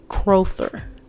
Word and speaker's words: Crowther: And his last name is Crowther, Crowther. So that's C R O W T H E R, right?